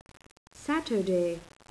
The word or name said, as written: saturday